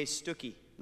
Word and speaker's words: Stookie: My running partner, my buddy in this marathon, is a friend of mine named Jay Stookie. Rhymes with Wookie.